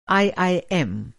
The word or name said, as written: आई०आई०एम